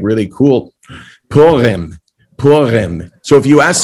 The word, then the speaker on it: Purim: It's Purim.